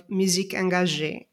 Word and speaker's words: musique engagée: But on the other hand, you have traced here a long trajectory of musique engagée. Again, please correct me if I'm mispronouncing it. – Perfect.